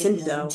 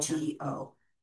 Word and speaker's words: Scinto: I think it's pronounced Cinto. – Scinto. Okay. – ENTO? – Oh, just T-O.